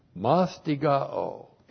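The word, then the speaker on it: Mastigao (μαστιγόω): Mastigao, M-A-S-T-I-G-O, long O.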